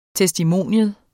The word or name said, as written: TESTIMONIUMS